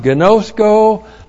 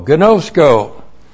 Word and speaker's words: ginosko: This is the present active participle of the verb ginosko, plus the accusative singular neuter of the demonstrative pronoun houtas. G-I-N long O-S-K long O. Ginosko. Ginosko.